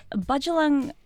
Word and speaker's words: Bundjalung: So in the Bundjalung? Yeah, that's a good way of pronouncing it.